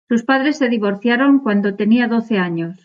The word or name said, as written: divorciaron